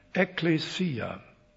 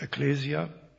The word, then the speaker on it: Ekklesia: Das griechische Wort für Gemeinde lautet Ekklesia. Zu Deutsch sagt man oft Ekklesia, das ist dann natürlich eine deutsche Aussprache.